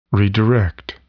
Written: redirect

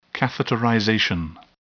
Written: catheterization

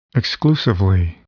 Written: exclusively

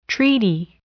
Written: treaty

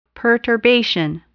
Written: perturbation

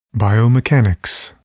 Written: biomechanics

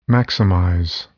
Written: maximize

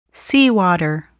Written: seawater

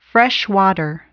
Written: freshwater

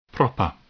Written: proper

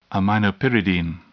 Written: aminopyridine

